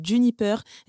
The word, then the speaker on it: JNPR: Réinventer sa façon de consommer au moment de l'apéro, c'est tout un concept que propose Valérie de Sutter, créatrice de la marque Juniper, euh, qui s'écrit JNPR.